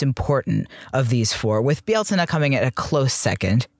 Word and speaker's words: Beltane: People do say Beltane, and that's the other thing with these pronunciations too. So depending on what part of Ireland or Scotland you're in, they're going to pronounce these differently anyway.